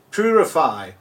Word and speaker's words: Puraphy: And your company is Purify. Have I pronounced it correctly? Say it again. Say it for me. – P-U-R-A-P-H-Y.